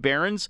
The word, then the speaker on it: Beirness: If you want to look him up, his last name is pronounced Behrens, but it's spelled like Beirness, B-E-I-R-N-E-S, if you want to check him out on HockeyDB or online.